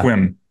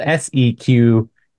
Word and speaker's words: Sequim: Squim. Yeah. – For all the folks who aren't in the Pacific Northwest, you're like, I want to hear more about this squim. It's spelled SEQUIM. – Right. – Sequim.